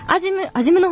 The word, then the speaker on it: 安心院: そこでですね、まあ、その、湯布院は、湯布院に行って、その後、もう美味しいご飯を食べて、アジムの方に行って、安心院と書いてあるんですね。